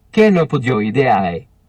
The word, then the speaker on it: Chenopodiidae: Chenopodiidae.